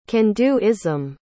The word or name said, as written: can-do-ism